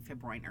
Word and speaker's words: fibroin: So the protein that we make silk, that silk fibers are made from, nature, silk fibers are made from a protein called fibroin. Such a 20th century word. Fibroin, it rhymes with groin.